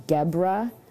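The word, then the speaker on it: Geburah: The pronunciation for 5, if I pronounce this correctly, is Gebra. It's kind of like Deborah, but with a G, G-E-B-U-R-A-H.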